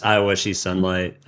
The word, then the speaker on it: I Wish You Sunlight: It's just I Wish You Sunlight. The reason you see Ga written on the track is it's the final one.